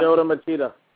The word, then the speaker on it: Lyoto Machida: Lyoto Machida.